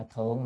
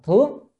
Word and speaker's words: thướng: À, chữ ư này chúng ta dịch là đối với, còn chữ chữ này không phải đọc là thượng mà đọc là thướng.